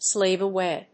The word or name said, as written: slave away